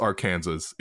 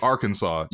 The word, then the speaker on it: Arkansas: And then they look at Arkansas, but it's not pronounced Arkansas. It's pronounced Arkansas.